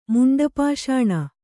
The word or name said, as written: ಮುಂಡಪಾಷಾಣ